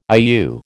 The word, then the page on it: IU: American English